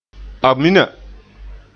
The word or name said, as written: amina